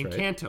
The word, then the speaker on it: Encanto: Uh, That's Encanto, right.